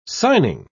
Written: signing

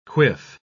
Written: quiff

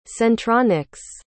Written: centronics

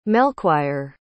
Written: melchoir

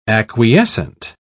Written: acquiescent

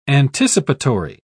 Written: anticipatory

